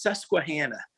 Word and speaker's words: Susquehanna: It's pronounced Susquehanna.